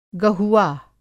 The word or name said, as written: गहुआ